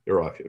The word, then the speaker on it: Iraq: Iraq. – Iraq, okay, I'm glad you pronounced Iraq correctly.